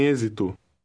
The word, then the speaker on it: êxito: In number 3, the X is pronounced like a Z. Read number 3. – O filme tem êxito.